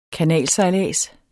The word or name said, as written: kanalsejlads